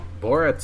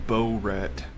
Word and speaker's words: Borat: One of my coworkers, they were talking about it, and she pronounced it Bo-Rat. – Yo, y'all like, seen that Bo-Rat? – Close enough. – I told her it's pronounced Borat.